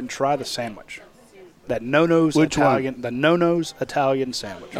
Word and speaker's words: Nonno's: N-O-N-N-O. – Oh, okay. I thought you said nose. – No. Nano's is three N's in there. N-O-N-N-O-S.